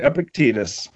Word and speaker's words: Epictetus: And I'm going to pronounce the name incorrectly, Epictetus. – No, that's um, right, Epictetus.